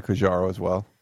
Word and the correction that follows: Quandro: Quandro, I'm sorry. I keep saying his name wrong, yeah.